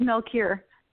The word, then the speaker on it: Melchior: It's Melchior.